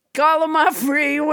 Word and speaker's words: Gallimaufry: Gallimaufry, which I pronounced wrong until nobody, James Hyder, corrected me.